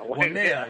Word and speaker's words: Waimea: Also in uh, I'm pretty sure I said that right. – Waimea. Waimea, yes.